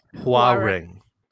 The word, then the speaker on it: Hwarang: So the way it's romanized, the H should be implied but not stated. – Yeah. It's Hwarang. Hwarang. And I think that W A is supposed to be a bit of a diphthong, as opposed to a straight vowel, but I'm not positive.